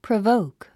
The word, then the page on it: provoke: prəvóuk; プラボウク